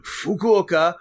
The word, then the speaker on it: Fukuoka: Fuck you, Okea. – Fuck you, Okea. Yeah, that sounds right. – Fukuoka.